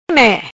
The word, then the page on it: Me: patua